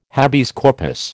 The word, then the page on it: habeas corpus: American English